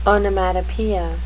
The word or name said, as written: onomatopoeia